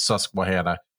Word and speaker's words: Susquehanna: Do you know some people pronounce it Susquehanna? – Susquehanna? – Mm-hmm. Yeah. That's wrong, but some people do pronounce it on Susquehanna, man. – My roommate from beautiful Yorktown Heights, New York, is like, no, it's Susquehanna. No, it's fucking not. It's Susquehanna. And I know that because I grew up next to it.